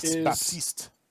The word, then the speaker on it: Baptiste: I think we mentioned it before, but Baptiste, I believe that's how you say his name. – Yes, Baptiste.